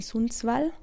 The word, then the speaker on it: Sundsvall: Du bist in Schweden aufgewachsen, in Sundsvall, habe ich das richtig ausgesprochen, ja.